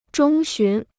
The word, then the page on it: 中旬: zhōng xún